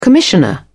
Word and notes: commissioner: British English

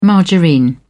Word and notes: margarine: British English

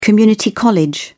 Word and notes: community college: British English

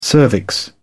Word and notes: cervix: British English

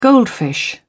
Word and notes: goldfish: British English

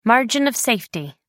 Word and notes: margin of safety: American English

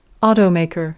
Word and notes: automaker: British English

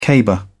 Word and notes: caber: British English

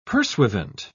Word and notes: pursuivant: British English

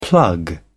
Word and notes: plug away: British English

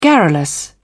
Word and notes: garrulous: British English

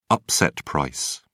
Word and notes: upset price: British English

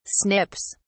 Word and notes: snips: British English

